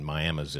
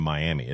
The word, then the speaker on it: Miami: But now Coweta's 5A and Miami's in 4A. But you have to make sure you do not pronounce it Miami, because it is in no way or form Miami. It is Miami.